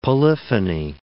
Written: polyphony

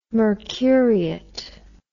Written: mercuriate

